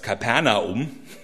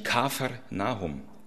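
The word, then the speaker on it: Kfar Nahum: Die Stadt, die wir gerne in Deutsch falsch aussprechen als Kapernaum, das klingt so schön lateinisch, heißt eigentlich Kafer Nahum, Ort des Nahum.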